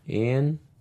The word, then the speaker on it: Iain: Ian. I think it's Ian. – Ian. – I-A-I-N is a weird way to say Ian, but okay.